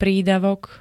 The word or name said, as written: prídavok